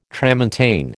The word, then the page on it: tramontane: American English